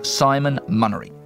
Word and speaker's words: Simon Munnery: I didn't say his name clearly, and I'm going to say it for you again, Simon Munnery.